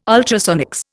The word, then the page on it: ultrasonics: Indian